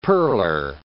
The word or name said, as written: pearler